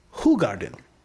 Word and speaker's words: Hoegaarden: And one of the beers they mentioned was the Belgian White, which we typically called Hoegaarden. – Which I've always heard everyone call it Hoegaarden. – And according to this pronunciation guide, it's Hoegaarden.